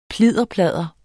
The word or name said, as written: plidderpladder